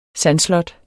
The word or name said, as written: sandslot